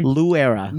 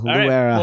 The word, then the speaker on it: Luera: Luera. – Luera. I didn't know if the L was silent or not. – Yes. No. Yeah. Full on L. Luera.